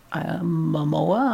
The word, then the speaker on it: Momoa: And I enjoyed Jason Momoa, I think is how he pronounces his last name, as Arthur, the Aquaman.